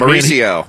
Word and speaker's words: Mauricio: This Maricio? Am I pronouncing it? Maricio Ruffy. I don't know how to pronounce it. But Mauricio. – Mauricio! I knew! Okay, Mauricio.